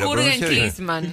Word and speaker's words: Jürgen Klinsmann: Jürgen Klinsmann.